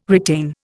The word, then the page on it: retain: Indian